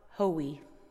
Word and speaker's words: Hoey: How do you say thank you in Caddo? – Hoey. – Hoey.